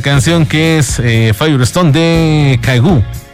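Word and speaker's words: Kygo: Bueno, Caigu, pronunciación, con Conrad Sewell, esta canción que es Firestone, dentro del disco Cloud Nine, primer álbum de estudio, primer sencillo.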